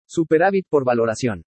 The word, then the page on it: SUPERÁVIT POR VALORACION: spanish